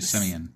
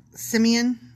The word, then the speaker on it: Simeon: How do you spell it? – S-I-M-E-O-N. – Oh, I would say Simeon. – Simeon. Or Simeon? – Not Samoan.